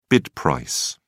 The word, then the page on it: bid price: British English